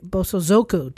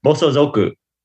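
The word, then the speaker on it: Bosozoku (暴走族): You're close. Bosozoku. – What did I say? – Bosozoku. Your inflection was all wrong, Liza.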